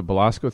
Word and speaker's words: Belasco: In Los Angeles at the Belasco Theater. Am I pronouncing that right? – Yeah.